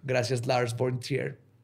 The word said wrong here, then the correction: von Trier: Gracias, Lars von Trier.